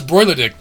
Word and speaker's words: Bruichladdich: I always started to pronounce Broiledick. The CH was silent.